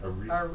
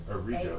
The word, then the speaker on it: Arrigo: How do you say that? – Arrigo. – Arrigo. – A-R-R-I-G-O. Yeah.